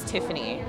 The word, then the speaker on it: Tiffannie: Like, my real name is Tiffany. It's spelled T-I-F-F-A-N-N-I-E. When I tell people, I'm like, it's Tiff and Annie with freckles.